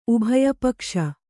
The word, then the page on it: ಉಭಯಪಕ್ಷ: Kannada